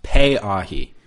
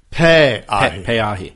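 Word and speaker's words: Pay-ahi (Peʻahi): This would be a good time to mention, I got an email from a listener correcting pronunciation on that surf spot, saying it's actually pronounced Pay-ahi. – Pay-ahi.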